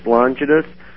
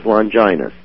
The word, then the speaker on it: Longinus: Well, I've heard it pronounced two ways, either Gaius Cassius Longinus or Gaius Cassius Longinus.